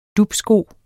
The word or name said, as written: dupsko